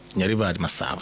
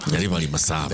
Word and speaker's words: Nyaribari Masaba: You can actually pronounce Nyaribari Masaba. – Nyaribari Masaba. – The correct accent. Very few people can do that.